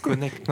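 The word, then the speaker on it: Knecht: Comment tu le prononces ? – Connect, visiblement. – Connect.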